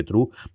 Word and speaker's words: Trieu: Lo del nombre yo creo que tiene dos partes interesantes, que es primero, que se pronuncia como verdad en inglés, es, no es, se escribe Trieu, pero realmente se pronuncia constantemente true. No sé en el caso español cuánto habrá cambiado, pero si oís la versión original americana, eh, suena true de verdad absoluta y total.